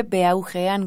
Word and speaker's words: Beaujean: Sí, exactamente, sí, con mi nombre y se escribe Beaugean con J.